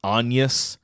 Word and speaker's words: Agnes: Or not the spelling, the pronunciation that O'Farrell... thinks was correct is Agnes.